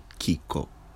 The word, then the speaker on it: kiko: The word for what is Kiko. Kiko. First syllable, Kiko. Accent and a first syllable, huh?